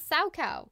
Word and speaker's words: Salchow: That is the sow cow. But it is spelled S-A-L-C-H-O-W. But it sounds like a sow, which is a female. – Yeah. – Yep, and a cow.